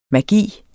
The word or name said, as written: magi